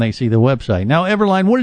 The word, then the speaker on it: Everlayn: It's E-V-E-R-L-A-Y-N, and now you understand why people pronounce the name the way that they do. E-V-E-R-L-A-Y-N. Borges, B-O-R-G-E-S, rhymes with gorgeous.